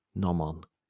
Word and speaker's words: gnomon: G-N-O-M-O-N. So with a silent G, this one. Nomon.